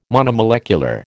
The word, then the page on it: monomolecular: American English